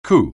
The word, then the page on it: coup: anglais